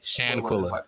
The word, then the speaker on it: Shanquella: But back on the whole Shanquella, I'm trying to really pronounce that right. – Shan is like Shan and then Quilla. – Okay. Shanquella.